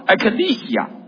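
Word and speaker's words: Ecclesia (ἐκκλησία): 所以呢，教会的希腊文的，大家还记得我说了好多次，希腊文的这个字的发音叫 Ecclesia。 Ecclesia 什么意思呢？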